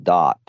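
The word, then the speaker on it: Daath: it's the invisible path called Dot. It's you know, spelled D AATH, but it's you know, pronounced Dot, and it's the path to your own subconscious or your unconscious.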